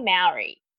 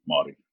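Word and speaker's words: Māori: I say Maori. is that like the Australian slang of it, or how am I supposed to be pronouncing it? – That's the foreign slang of it, yes. – Because my best friend is Maori, so where have I been? – Maori. – I don't even think I can say it like that. – So it's I'm trying to figure out my. So you roll the R like a D? – See, I can't roll anything.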